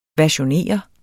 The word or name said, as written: versionere